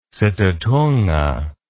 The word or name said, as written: situtunga